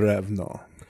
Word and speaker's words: Ravnos: The Ravno. – Some debate in the community about how it's pronounced. Regionally, we've always pronounced it Ravno. I always thought the S was silent.